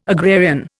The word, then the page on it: agrarian: Indian